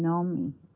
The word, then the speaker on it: Noemi: My mother used to tell the story how when I was born and she gave me the name Naomi, that's the Hebrew pronunciation of the biblical Naomi, and then Noemi, the way it is spelled, is the Hungarian spelling.